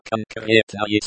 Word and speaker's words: конкретное: Это конкретное событие очень интересное. This particular event is very interesting. Pronunciation. Конкретное. Конкретное. Con, like, con, in, cone. Crate, like, crate. Nayer, like, nay, in, say.